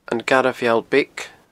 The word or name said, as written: Garbh Mheall Beag